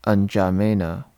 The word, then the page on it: N'Djamena: English